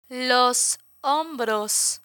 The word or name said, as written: los hombros